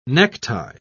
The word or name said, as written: necktie